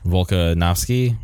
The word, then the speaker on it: Volkanovski: Alex Volkanovski.